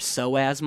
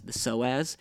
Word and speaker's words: psoas: I have this other tool called the Sorite, which is meant to stretch your psoas muscle, which I encourage you to look up what that is if you don't know what it is. It's spelled P-S-O-A-S, the psoas.